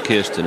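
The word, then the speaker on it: Kirstjen: How do you pronounce... Yeah, I've had a J in it. – Diana says Kirsten, and she's a professional pronouncer.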